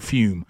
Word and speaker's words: Fum: So I want to, you know, join the guys and introduce you to a great product called Fume. Now, it's spelled F-U-M, but they pronounce it Fume, okay?